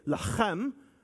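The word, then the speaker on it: lachem (לחם): Lehem, or as they say, lachem. You got to say it like you got some phlegm in your throat.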